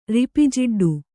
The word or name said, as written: ರಿಪಿಜಿಡ್ಡು